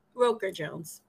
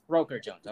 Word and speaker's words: Roker-Jones: Um, but anyways, yeah, give it up for uh, Marie Marie Rocker Jones. Is that did I pronounce that right? Roker Jones. Roker Jones. Okay, my bad. – My bad. Better than okay. Um, most people. Wait, what'd you say? I said you pronounced it better than most people. How do most people pronounce it? Um, I've been called Walker, Roper, Rocker, um, Rooker.